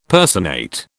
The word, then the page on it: personate: British English